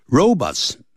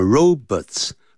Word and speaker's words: robots: Robots, 啊这个字不要念成 robots, 叫 robots。